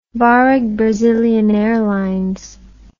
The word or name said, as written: Varig Brazilian Airlines